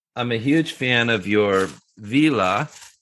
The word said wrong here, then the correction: Vela: Am I saying that right? Vila? – No. Well, you can say it any way that you want. I will say it's Vela. We pronounce it Vela, and it sounds better when our Italian team says it.